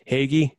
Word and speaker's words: hoagie: hoagie every day. Uh, agree. The only yeah. thing I'm going to correct you on is it's pronounced Hagee.